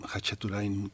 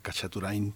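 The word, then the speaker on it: Khachaturian: Él es Aram Hachaturain, Kachaturain también se pronuncia, se tiene distintas grafías.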